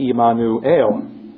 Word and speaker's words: Immanuel: the Hebrew, pronounced something like Immanuel. You can see the closeness between Immanuel and Emmanuel.